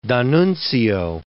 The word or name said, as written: D'Annunzio